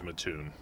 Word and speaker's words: Mattoon: Apparently it is Mattoon. I think I may have been relaying a derogatory alternative pronunciation.